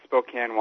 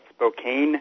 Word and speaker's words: Spokane: Um, yeah, I come from my hometown. It's called Spokane, Washington, but people from everywhere else seem to call it Spokane, mm.